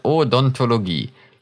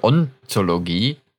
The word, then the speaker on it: Odontologie: Guck, habe ich richtig gesagt. Odontologie. Weil manche sagen das falsch, dann sagen die irgendwie Ontologie.